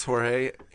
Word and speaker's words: Jorge: Instagram, he's got a very great Instagram, and it's pronounced Jorge. How do you spell the phonetical? – H-O-R-H-A-Y, yeah. – H-O-R-H-A-I. – H-A-Y. So it's, the words pronounced H-O-R-H-A-Y.